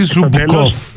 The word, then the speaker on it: Ζούμπκοφ: Ή Ζούμπκοφ, θέλω να τα προφέρεις τα ουκρανικά σωστά, ε.